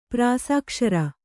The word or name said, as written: ಪ್ರಾಸಾಕ್ಷರ